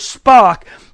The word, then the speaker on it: Spaak: Hey, listen, there was a man by the name of Spock. His name is spelled S-P-A-A-K. He is Henry Spock.